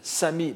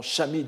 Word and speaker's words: shami: L'auteur en est celui que l'on appelle Sami Manse ou Sami Manze, c'est-à-dire Manze le moine novice, euh, Sami, ou que l'on prononce plus euh, correctement shami dans les textes bouddhiques, mais c'est japonisé en sami, c'est l'abréviation de shramanera, n'est-ce pas, celui qui, qui, commence à faire effort dans la voie, le, futur shramana.